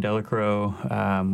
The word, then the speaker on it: Delacroix: Started around the city, and then eventually ended up down in the marsh out of Hopedale and out of Reggio and Delacro, which I pronounced Delacroix for at least the first two months.